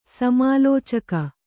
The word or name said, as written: ಸಮಾಲೋಚಕ